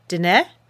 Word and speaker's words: Diné: I pronounced this term incorrectly and I want to try to correct it. It should be pronounced DNA.